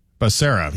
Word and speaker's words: Becerra: How do you say this guy's name? Becerra? Xavier Becerra. – Becerra.